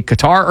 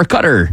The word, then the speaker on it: Qatar: But that both are Englishizations. Qatar, Qatar, that's not how they say it.